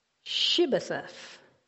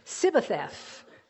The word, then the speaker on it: Shibboleth: It was whether or not they could pronounce the word Shibboleth. Shibboleth. Versus they couldn't say the H. For whatever reason, their dialect was different. So they would say Sibbetheth. Try this out. S-H, Shibboleth, or Sibbetheth. It's real slight.